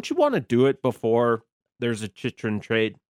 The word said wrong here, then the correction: Chychrun: Wow, that pronunciation. – Chychrun. – There are not as many CHs in Chychrun as I just indicated for those spelling at home.